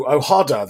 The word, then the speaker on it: OHADA: Now, OHADA, the Organization for Harmonization of Business Law in Africa, that's the Anglophone pronunciation anyway, being a great example and advocate for such.